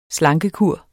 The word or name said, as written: slankekur